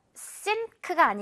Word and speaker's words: sink: 우리 주방에 있는 그 싱크대 이야기할 때도 할 수 있는데 이거 sink가 아니겠죠. 이 N이 생긴 거 그대로 니은처럼 소리 나지 않고 NG처럼 sink 이렇게 발음이 나야 한다는 거죠.